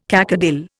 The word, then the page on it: cacodyl: Indian